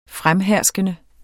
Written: fremherskende

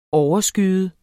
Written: overskyet

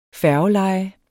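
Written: FÆRGELEJE

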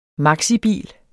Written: maxibil

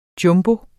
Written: jumbo

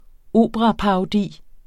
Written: operaparodi

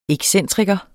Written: EXCENTRIKERNES

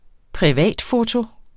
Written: privatfoto

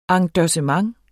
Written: endossement

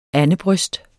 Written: andebryst

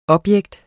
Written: objekt

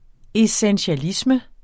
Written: essentialisme

